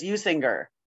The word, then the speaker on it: Usinger: And they had the Bob Uecker commercial on the radio where he says it, and he's the spokesperson, and he says Usinger. – Ooh, the hard G, not the hard JG.